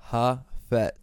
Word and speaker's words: Jafet: My name is Jafet Rodriguez. I'm Dominican and Puerto Rican. – Teach me how to say your name. – Jafet. Imagine a H instead of a J. So imagine it's spelled H-A-F-E-T.